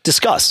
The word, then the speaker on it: Disqus: And I've seen um, there's a, a, comment or a discussion service, uh, Disqus, D-I-S-Q-U-S. – Discus, D- yeah, I, discuss.